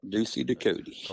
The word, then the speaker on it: Ducie Ducote: Look, man, I've been called everything from Ducote to Ducote to Ducote. You name it.